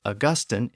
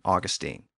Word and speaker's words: Augustine: First, Augustine is typically pronounced Augustine in Britain, but I'm going to use the other pronunciation, Augustine.